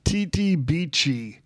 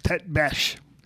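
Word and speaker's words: tête-bêche: So my son, Sean, gave me a phone call today, and he said, what is Tete Beachy? And because he's going through his stamps, and he saw the listing in Scott's catalog. And I said, well, it's pronounced Tete Besh.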